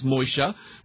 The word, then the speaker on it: Moshe: His name properly is Moshe, which is spelled Mem, Shin, He.